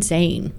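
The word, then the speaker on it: Zayn: Wait, if I'm on fire, and that was Trampoline by Shade featuring Zane. If I said that correctly. I don't know. I second guessed myself as soon as I said that. – No, you said it right.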